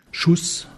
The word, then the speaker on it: Xousse: Zum Beispiel Schuss, das wird mit SCH ausgesprochen. – Und das Schuss schreibt sich? – X-O-U-S-S-E. – Und das spricht man dann Schuss.